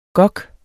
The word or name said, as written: gok